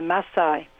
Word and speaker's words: Maasai: We're going into the very remote place away from the tourist routes to live with the Maasai, is the correct pronunciation.